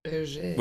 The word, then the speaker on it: Hergé: And it's spelled H-E-R-G-E. – Hergé. – Which, again, I always called Herge growing up. Not Hergé.